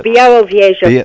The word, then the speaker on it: Białowieża: And plus, you forced me to go to, see if I can pronounce this correctly, the Białowieża Forest. I can't pronounce it. – Oh, Białowieża Forest. – Białowieża Forest. There we go.